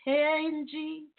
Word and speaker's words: Tangie: So we're expecting Bobby V and Miss Tangie, I believe I'm pronouncing that correctly, T-A-N-G-I-E, sounds like Tangie to me, Tangie B.